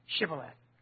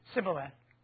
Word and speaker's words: Shibboleth: And if he said no, then they would say to him, "Say now, Shibboleth." But he said, "Sibboleth," but he could not pronounce it correctly.